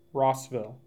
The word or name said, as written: Rossville